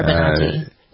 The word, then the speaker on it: Benanti: Is it Benanti? How do you pronounce it? Benanti is how it's spelled. Benanti. – Yeah, Laura Benanti.